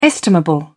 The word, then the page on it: estimable: British English